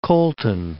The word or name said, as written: Colton